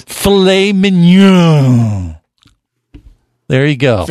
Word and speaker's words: filet mignon: Filet mignon for everyone. – Yeah, that's right. A little filet mignon.